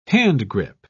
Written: handgrip

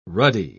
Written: ruddy

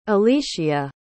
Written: elishia

